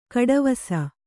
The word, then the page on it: ಗೆಡೆವಸು: geḍevasu